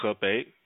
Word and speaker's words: Hebei: And it's pronounced Hebei. It's from the north of China.